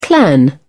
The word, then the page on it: clan: British English